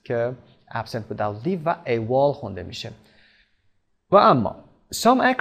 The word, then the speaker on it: AWOL: A-W-O-L که تلفظ میشه AWOL، AWOL absent